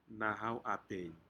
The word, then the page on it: nahau apen: Aneityum